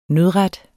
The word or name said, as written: nødret